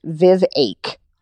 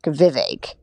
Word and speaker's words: Vivek: They like see DeSantis as like the main alternative, but their hearts were with Vivek Ramaswamy, who, what? By the way, his name is pronounced Vivake, like a heartache, Vivake.